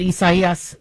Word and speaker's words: Isaias: So, the media is reminding us that we are pronouncing it Isaias, so because that is the Latino term for Isaiah. And so even though Isaias is where how many of us Americans would call it, the Spanish pronunciation is what they are using.